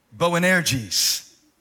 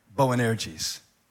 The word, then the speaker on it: Boanerges: And to them, he gave the name, let me try to say it right, Boanerges. Boanerges.